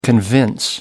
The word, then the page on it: convince: American English